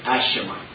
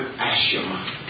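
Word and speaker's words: Aeshma: We're using as a subject today, and I'm going to spell this word, it's A-E-S-H-M-A, Ashema. There are different pronunciations, but let's go with Ashema. It's A-E-S-H-M-A.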